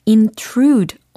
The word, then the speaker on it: intrude: I-N-T-R-U-D-E 라는 철자예요. 어떻게 발음이 될까요? intrude. 이렇게 하시면 돼요. intrude, intrude, intrude. 하고 계십니까? 네. 그래서 intrude 아니고, intrude 아니고, intrude. 이렇게 이음절 강세에다가 주시고 정말 침범해 오는 것 같은 느낌이 드는 것처럼 intrude 이렇게 쑥 들어오게 intrude 이렇게 발음을 예, 해주시면 되겠습니다.